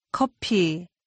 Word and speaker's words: 커피: 커피. 피. 커. 커피. 커피. – Say coffee. Be sure to pronounce this word as Koreans do.